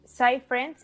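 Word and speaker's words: PsiFriend: Es SciFriend, se escribe P-S-I, Friend, de amigo en inglés, y el número 7.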